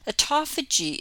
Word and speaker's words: autophagy: Autophagy is spelled AUTOPHAGY. I have heard it pronounced two ways, autophagy or autophagy. From what I can tell, either one seems to be correct.